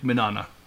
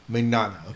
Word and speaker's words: Mignogna: How do you say it? – Vic Mignogna. – Mignogna. Okay, so yeah, that guy.